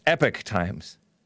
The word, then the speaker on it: Epoch Times: It looks like Epoch Time, the Epoch Times, but it's pronounced Epoch Times.